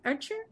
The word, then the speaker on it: Ötscher: The Ötscher, I'm saying it correctly? Ötscher? – Yeah.